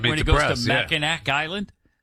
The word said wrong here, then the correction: Mackinac: Oh, no, Mackinac Island. – Mackinac Island. – First of all, yeah. – It's spelled, why do they spell it Mackinac when it's Mackinac?